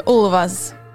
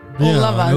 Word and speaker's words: Ulvaeus: Ulvaeus. – All of us. – Ulvaeus.